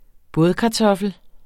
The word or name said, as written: bådkartoffel